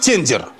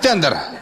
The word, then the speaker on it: тендер: Нашу страну погубит тендер. Ну, правильно говорить тендер. Но я просто вас вижу, почему-то хочу неправильно говорить.